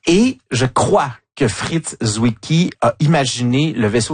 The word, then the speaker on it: Zwicky: Donc un, Fritz Zwicky, ça s'écrit Z-W-I-C-K-Y. Donc je sais pas si c'est Zwicky si on le prononce est-européen. Mais en tout cas, bon, c'était Fritz Zwicky, hein, c'est ça? – Oui, exactement.